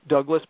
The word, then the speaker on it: Douglas: Douglas, my first name is spelled with one S, and Dietrich, my last name, is spelled just like the famous actress Marlena Dietrich.